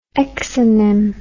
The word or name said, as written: exonym